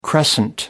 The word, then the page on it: crescent: American English